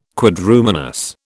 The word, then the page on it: Quadrumanous: British English